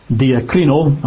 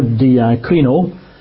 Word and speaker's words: diakrino: And the Greek word is diakrino. I'll spell that if you're taking notes. It's spelt D for donkey, I-A-K-R-I-N-O, diakrino.